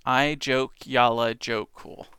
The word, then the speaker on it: Eyjafjallajökull: Pronounce the J as Y. Eyjafjallajökull. Eyjafjallajökull.